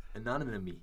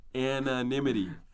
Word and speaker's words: anonymity: Do you actually think it's anonymy? – Anonymity. – Anonymity.